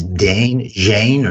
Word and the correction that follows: Zayn: Zane. – Is that who it was who left? Zane. Yeah, Zane. That's spelled Z-A-Y-N.